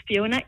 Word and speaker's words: Phiona: Yes, so I'm on Twitter, Miss Fiona, MS, and my name is spelled with a PHIONA. If you also Google my name, Fiona Martin, you'll come across my website where I've got a lot of career advice content.